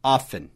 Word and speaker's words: often: Often, O-F-T-E-N, often, não pronuncie o T, tá?